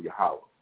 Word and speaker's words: Yahweh: And when it says Yahweh, I was talking about, we know it's pronounced properly in Hebrew, the Paleo-Hebrew, Yahweh.